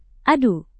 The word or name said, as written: adu